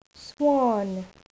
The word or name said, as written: swan